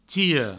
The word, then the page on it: tira: tiro